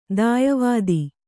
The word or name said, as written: ದಾಯವಾದಿ